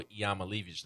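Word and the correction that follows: Iamaleava: name. – What? That's not it either. – Hold on. Let me think. I know how to say it when I hear it. I just can't remember how to say it. That's my problem. Nico Iamalava. – Oh, my. Nico Iamalava. – Ah, that's right. Iamalava.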